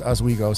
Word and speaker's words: Oswego: Yes, and it's called it's pronounced Oswego. So, you know, that was a very very mediocre way to say that town.